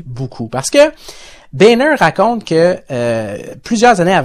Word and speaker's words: Boehner: Boehner. – Boehner.